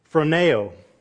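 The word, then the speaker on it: phroneo (φρονέω): And it's pronounced phroneo, to position yourself, to exercise the mind, to be intensively interested, and to set your affections on.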